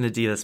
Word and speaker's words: Adidas: So, taking you over to the West Coast to Lake Tahoe area, or South Lake Tahoe area, because this guy, Adidas, as I believe it is pronounced. – That's a weird way of saying that. – Exactly. I feel very strange saying that, but emphasis on the eyes in Adidas, because guess what?